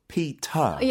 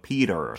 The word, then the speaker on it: Peter: 피터, yeah. 피터, 피터. 그 T 발음 진짜 해야 되잖아요. 네. 영국식에서. 네. Yes. 근데 미국에서 Peter, Peter. 약간 flap sound처럼 uh-huh. 했잖아요. 그래서 왜 제대로 안 했냐 미국 사람들한테 물어보는데.